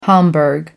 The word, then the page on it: homburg: American English